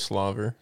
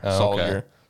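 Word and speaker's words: Salyer: Jamari Slaver. Slaver. – Slayer? – Is it Slayer or Slaver? – Is it? I think it's Slayer. – Slayer. Sawyer. Sawyer. It's S-A-L-Y-E-R. Sawyer. – Sawyer.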